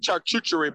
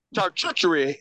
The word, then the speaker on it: charcuterie: And I bought like a big charcuterie board, you know? Did I say that right? – No, but go ahead. – Charcuterie. How do you say it? – Charcuterie.